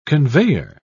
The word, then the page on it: conveyor: British English